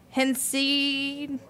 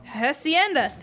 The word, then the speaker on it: Hacienda: We're going to try Hacienda. – Hacienda, thank you.